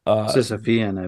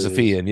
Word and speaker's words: Sisyphean: felt like she's sort of taken this like Sisyphean, if that's the proper way to pronounce that word, task of holding back. – Sisyphean, I believe. – Sisyphean, yeah. Of holding back Garfield.